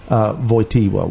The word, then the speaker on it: Wojtyla: But just any other last thoughts about, like, just why would I send my kid there to Camp Wojtyla?